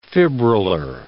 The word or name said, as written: fibrillar